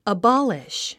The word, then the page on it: abolish: əbɑ’liʃ; アボリシュ